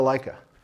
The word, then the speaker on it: Leica: Is it Leica? Am I pronouncing that right? – Yeah, yeah.